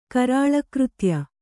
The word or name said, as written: ಕರಾಳಕೃತ್ಯ